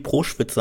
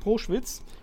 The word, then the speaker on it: Proschwitz: Du rätst aber, Nick Proschwitz oder Proschwitz, keine Ahnung, wie man ihn wirklich ausspricht. Ich habe ihn immer hart ausgesprochen und es stimmt tatsächlich.